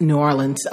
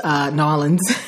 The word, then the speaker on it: New Orleans: For those of you who have not traveled to New Orleans before, yes, I pronounce it New Orleans, Nolens, as some of my friends down there would say.